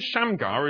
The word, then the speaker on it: Shamgar: The name Shamgar is, well, it's got two syllables in English. It has two syllables in Hebrew, and each syllable sounds a little bit like something which could be a Hebrew word. The word Sham sounds a bit like Shem, which means, anybody know what Shem means?